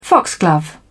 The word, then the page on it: foxglove: British English